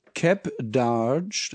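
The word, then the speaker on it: Keb Darge: Der Sampler heißt, der heißt Cap Darge. Das schreibt man K-E-B-D-A-R-G. Das scheint ein Name zu sein. K-E-B. K-E-B und dann Darge.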